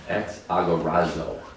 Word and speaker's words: exagorazo: Did I pronounce that correctly? Exagorazo? – Exagorazo. – Yep.